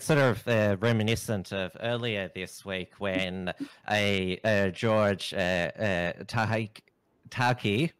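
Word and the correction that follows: Takei: I mispronounced Takai, it. Right? – Takai, yes.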